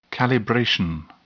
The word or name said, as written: calibration